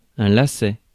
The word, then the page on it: twist: /ˈtwɪst/; [ˈtwɪst]